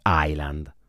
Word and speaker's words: island: Isola in inglese si dice island, perché la S non si pronuncia, è scritto Island.